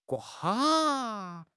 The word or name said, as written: gua4